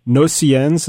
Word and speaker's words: Nocturnes: is this Satie's Nocturnes. Is that how you say it? – Nocturnes, yeah.